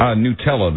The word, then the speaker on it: Nutella: That's Nutella? – That's one. Is that how you pronounce it? I don't know. Nutella, yeah. – I guess. – Nutella, right.